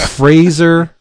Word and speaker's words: Fraser: X-O-M-B-I, Zombie, by John Rosum. This new series is written by Rosum with art by the unbelievable Fraser... rhymes with laser, Irving.